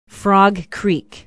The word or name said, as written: Frog Creek